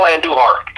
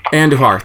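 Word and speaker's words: Andújar: Who's the Andujar or something? – Miguel Andujar. – Andujar, thank you.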